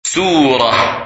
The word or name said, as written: Surah